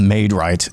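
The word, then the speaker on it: Maid-Rite: So I got a job at this place called Maid Right. It's spelled MAIDRITE.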